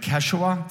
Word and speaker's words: Quechua: the language of Quechua. Did I say that correctly? Awesome.